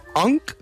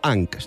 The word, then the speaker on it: UNC: És una paraula que ha popularitzat la generació alfa i atenció perquè la pronúncia és complicada. Són tres lletres. UNC és ONC, UNC o ANC. Depèn d'on siguis i de com ho pronuncis.